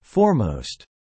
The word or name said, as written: foremost